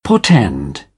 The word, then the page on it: portend: British English; ɔː